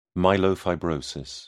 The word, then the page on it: myelofibrosis: British English